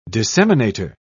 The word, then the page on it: disseminator: British English